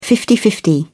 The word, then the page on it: fifty-fifty: British English